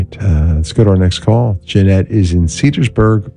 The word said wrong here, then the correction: Cedarburg: Wisconsin. Cedarburg. Did I say that right? Yeah, Cedarburg.